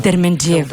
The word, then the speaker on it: Дерменджиева: Да, Дерменджиева. – Дерменджиева. – Да, ударение, да. Правилно.